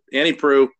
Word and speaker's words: Proulx: Annie Proulx, who's come up, or Prue actually is the way that you supposedly the way that you pronounce it. Annie Proulx, she's come up on this podcast many times before.